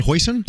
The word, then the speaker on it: hoisin: Hoisin? – Hoisin.